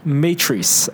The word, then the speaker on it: Matrice: Matrice. – How do you spell it? – M-A-T-R-I-C-E.